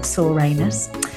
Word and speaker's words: Soranus: According to the ancient Greek physician Soranus, I don't know if that's how you pronounce it, it's spelled S-O-R-A-N-U-S. Let's call him Soranus.